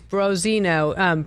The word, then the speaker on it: Branzino: He said, you said it wrong. It's Branzino. Branzino is what you ordered at La Scolio.